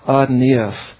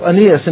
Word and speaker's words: Aeneas: So I call this, I pronounce his name Aeneas, all right? Aeneas. We have Aeneas, and he is a man who is from this town called Lydda.